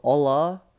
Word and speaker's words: hola: So hola is HOLA, and their H's, they don't pronounce their H's, they're silent. So you go hola, it sounds like OLA, and that means hello.